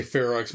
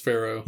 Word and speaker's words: Ferox: I think Americans would say Ferox, but I believe it actually is pronounced Pharaoh.